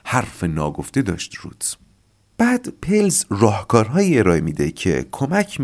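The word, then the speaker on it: Perls: چرا من از این به بعد به آقای پرلز میگم آقای پلز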